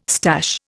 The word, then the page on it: stash: Indian